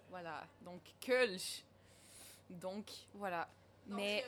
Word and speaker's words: Kölsch: Parce que la plupart penseront à une influence belge ou européenne en général, qui vont dire Kölsch, qui, selon moi, puis mes expériences, est erronée. À vous la parole. – Euh, moi, je dirais Kölsch, personnellement. Comment on le prononce? Kölsch. – Parce que. – Kulch. Parce que le O.